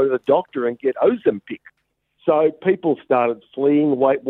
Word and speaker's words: Ozempic: And, of course, she did late last year admit that she was taking this drug, Ozempic. Is that how you say it? Ozempic? – Ozempic? – Ozempic. Yeah. Ozempic. That's it.